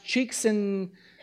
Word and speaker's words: Csikszentmihalyi: It's pronounced Csikszentmihalyi. It's spelled Csikszentmihalyi.